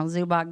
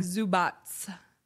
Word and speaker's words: Zubac: Well, apparently if you got, you know, Zubac getting 17 Zubats. Rebounds. Is that how they pronounce it? – Yeah. – I'm never going to get good at these names ever. – Zubats.